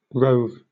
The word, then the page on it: rove: British English